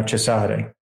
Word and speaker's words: Cesare: Yeah, so the Italians, they would pronounce that name now Cesare, right?